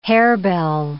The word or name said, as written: harebell